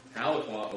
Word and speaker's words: Tahlequah: That's in, I hope I'm saying it right, Tahlequah. Saying that right? All right, sweet. Tahlequah, Oklahoma.